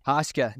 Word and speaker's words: Haschka: Haschka.